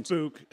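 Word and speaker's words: Bouc: It's Book. His name's Book, not Bok.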